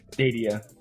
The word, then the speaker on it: Stadia: Did I pronounce that right? Or is it Stadia? – Stadia. – Okay, yeah.